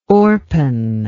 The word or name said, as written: Orpen